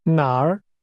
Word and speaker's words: 哪儿: That's pronounced with a falling, rising tone. Say again, where? – 哪儿?